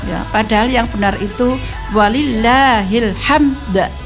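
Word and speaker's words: Walillahil hamd (ولله الحمد): Misalkan walillahil hamdah. Walillahil hamd, enggak ada de-nya.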